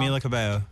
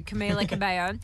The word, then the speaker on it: Camila Cabello: Camila Cabello. – Thank you, Camila Cabello.